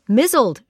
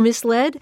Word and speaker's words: misled: My mispronounced word, which launched the series, was mizzled for misled.